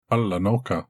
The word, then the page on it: Alanoka: Galindri